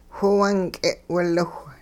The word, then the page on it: whiwung k'e'wiliwh: Hupa